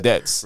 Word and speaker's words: debts: See, when you say debts, there's no debits. It's debts.